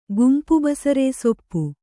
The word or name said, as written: ಗುಂಪುಬಸರೇಸೊಪ್ಪು